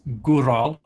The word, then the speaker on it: gwrol: It is spelt G-W-R-O-L. – Gettle. – It's Gural, and it means brave,